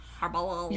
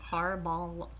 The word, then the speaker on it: Carballal: How would you say that? Carballal? Carballal. – You're going to have to spell it for me one more time. – C-A-R-B-A-L-L-A-L. Carballal. – C-A-R-B-A-L-L-E-L? – A-L. – Yeah, Carballal.